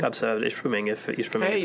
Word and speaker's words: Ishpeming: Hey, you said it right. Look at, good job. Last week he knew what odd numbers were. And this week, he finally learned how to say Ishpeming correctly.